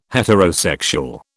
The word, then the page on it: heterosexual: British English